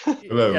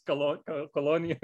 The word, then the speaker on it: Cologne: Of Cologne, how do you say it in English? – Cologne. – Yeah. I always mix it up. Should it be Köln or should it be Cologne? It's Cologne. Yeah, it's weird.